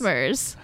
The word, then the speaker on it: Smrz: He has no vowels in his last name. – S-M-R-Z. – Yeah, it's pronounced Smurz.